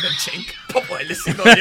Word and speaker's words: Genk: Y yo pensé que se decía Genk. – Ajá, por eso, yo también decía Genk. No, déjame chequearlo. – Yo miro a esto y me dice, estoy corroborando la pronunciación. – Chenk. Ok, perfecto, gracias. Y así mismo lo tengo escrito, Chenk.